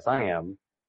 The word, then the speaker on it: SIEM: So the SIM tool, pronounced SIM, SIM tools are the AWACS out there looking over your network, soaking up data, letting you see what's going on.